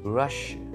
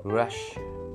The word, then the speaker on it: Russia: Russia. You have to stress on the first R-U-S. Russia.